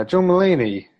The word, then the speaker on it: John Mulaney: Yeah, John Mulaney.